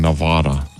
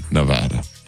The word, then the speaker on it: Nevada: And the next toughest state to pronounce is Nevada, because people can't stop saying Nevada. – Nevada. – Instead, it's preferred Nevada.